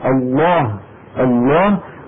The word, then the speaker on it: الله: فنقلت هذه الكلمة على الألسنة قبل نزول القرآن بأزمنة متطاولة، نطقتها العرب الله، وفخموا لامها، ونزل القرآن بتفخيم لامها كما مر معنا الله.